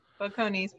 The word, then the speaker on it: Balcones: Balcones.